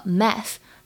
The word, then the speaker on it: math: 还有就是最后的这个 math，它末尾的 th 字母组合呢发的是咬舌音，一定要记住要咬舌尖来发音。